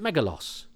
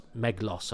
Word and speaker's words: Meglos: The other thing was, for years, for a short time, I pronounced it Megalos, because we watched it at the time and I talked about it to my friends at school and we called it Megalos. And even when I got the book and it was Meglos, I was like, well, do they just not pronounce the in the middle then? Because it's not Meglos. Meglos sounded wrong to me.